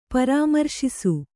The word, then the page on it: ಪರಾಮರ್ಶಿಸು: parāmarśisu